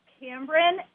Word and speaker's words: Cambron: And you almost got it. It's Cambron.